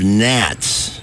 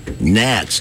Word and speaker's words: gnats: There is a giant outbreak of gnats. Gnats, G-N-A-T. – Not gnats. – which coincidentally rhymes with not that.